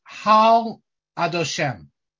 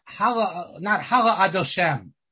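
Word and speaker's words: Hal Adoshem (הֲלַה'): This verse 6 in Hazinu, is actually to be pronounced Hal Adoshem, not Hala Adoshem or anything like that.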